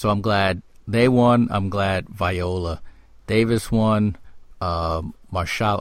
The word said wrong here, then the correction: Mahershala: I hope I pronounced his name right. I'm glad he, Mahershala. – Mahershala.